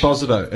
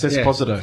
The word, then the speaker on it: Esposito: How do you pronounce it? – Can't you read? – Esposito? Is that Spanish? – Esposito. It's Esposito. – Esposito.